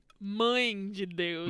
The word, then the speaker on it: mãe de Deus: And that just then, Max says, "Olha, mão de Deus," and we see a tornado. Wait, what did he say? Did I say it wrong? – I think it's supposed to say, mãe de Deus.